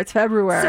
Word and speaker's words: February: Yeah, because there's an R in there. It's February. – So it is pronounced that first R? – Yes, oh, God. – It's February, yeah.